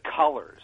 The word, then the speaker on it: Cullors: That's how she pronounces it, which I wasn't sure about until I watched this video where she says, hi, I'm Patrice Cullors, right? So I actually heard her say it.